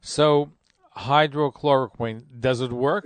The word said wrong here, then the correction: hydroxychloroquine: You're saying that wrong. It's hydroxychloroquine.